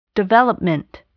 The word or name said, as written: development